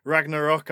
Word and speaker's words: Ragnarok: We're going to be looking at the Norse apocalypse, which is Ragnarok. I'm sure I'm butchering the pronunciation of it. – Oh, you're fine. Don't worry about it. It's an English word too nowadays.